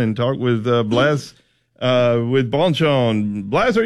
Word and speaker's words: Bonchon: Okay, with my Alabama dialect and all that, I want to make sure I'm saying your name correctly and Bonchon.